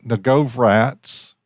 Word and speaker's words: Novogratz: Billionaire Mike Novogratz, I butchered that name. – I think you said it perfectly.